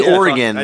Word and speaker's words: Oregon: Ooh, watch your pronunciation. – Oregon. – It's Oregon.